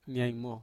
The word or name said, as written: niaimo